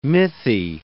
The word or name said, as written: mythy